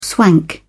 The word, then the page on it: swank: British English